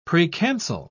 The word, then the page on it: precancel: British English